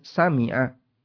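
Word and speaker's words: سَمَّعَ: وَضَبَطَهُ الْخَطَّابِ Sementara Al-Khattabi dan yang lainnya membaca dengan Sami'a. Nggak pakai tasydid, dibaca Sami'a. Sehingga di situ ada dua cara baca. Bisa dibaca dengan Sam'a, bisa dibaca dengan Sami'a. Baik, namun yang ma'ruf adalah dengan ditasydid, Sam'a.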